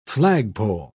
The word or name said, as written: flagpole